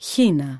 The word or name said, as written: χήνα